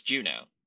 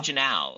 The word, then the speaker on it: Juneau: The city like Juneau is spelled weird, but it's Juneau. – Yeah, did I say it wrong or? – Okay, I think you said Janal, and I was like trying to figure out.